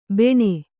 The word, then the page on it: ಬಾಣಿ: bāṇi